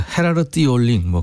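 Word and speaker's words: Gerard Joling: 제럴드 졸링은 이제 영어 표기고요. 네덜란드 가수예요. 그래서 헤라르트 올링 뭐 그렇게 발음한다네요.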